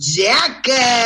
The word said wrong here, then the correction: Shakers: Los Shakers serán. Se escribe Shakers, se pronuncia los Shakers.